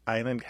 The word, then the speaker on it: island: Island has a little bit of French history. The S is not pronounced in French. Therefore, we don't pronounce it in this word island. So I'm going to recommend a book. It's by Erica Okrent, O-K-R-E-N-T.